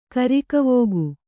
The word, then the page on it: ಕೃಕವಾಕ: křkavāka